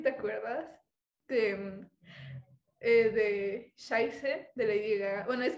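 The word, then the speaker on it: Scheiße: tiene hay una letra en alemán que parece una B, pero en realidad es, es como un dos S, se pronuncia como dos S. Entonces, en sí, lo estábamos pronunciando mal, o se pronunciaba Chevy eh, y la E y la I se pronuncian I, entonces se pronuncia Shaise.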